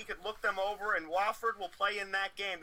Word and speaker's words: Wofford: It's Wofford.